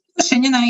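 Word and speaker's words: Ushenina: Ushanina, yeah.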